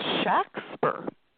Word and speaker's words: Shakespeare: It looks like the great artist, the great author's name, but actually it was pronounced Shakespeare. If you look at the documentary records of the times, they would spell things phonetically, and it was usually S-H-A-X-P-E-R, S-H-A-C-K-S-P-E-R, something like that, where it's a short A, Shakespeare.